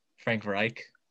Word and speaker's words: Frank Reich: But I feel like he can improve under Frank Reich and. Wait, did I say his name wrong? – Frank Reich. – Right. Yeah. My bad.